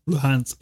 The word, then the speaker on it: Luhansk: Luhansk, yeah.